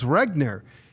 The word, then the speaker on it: Regner: He sits down, he looks at me, and he sees my name, and he goes, Regner. He goes, that's German.